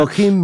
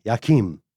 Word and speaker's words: Joachim: I think in French it's Joachim, something like that. – Yeah, Joachim. Yeah. Okay. – I think the J is a little more apparent. – A little more pronounced.